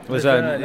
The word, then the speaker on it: Luzern: Luzern? – Luzern, yeah.